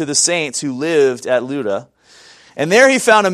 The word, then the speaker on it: Lydda: That's how that's pronounced, Lydda. Lydda is, but Lydda.